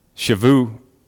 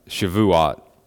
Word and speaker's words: Shavuot: The Hebrew word, uh, shavuot, I believe is how you pronounce it. Uh, shavuot.